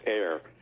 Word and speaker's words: Ayr: The village I came from was spelled A-Y-R and pronounced Air.